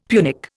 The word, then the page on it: Punic: Indian